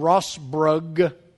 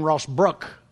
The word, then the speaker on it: Rossbrook: So like John Rossbrug, that's how I used to always say it. His name is John Rossbrook.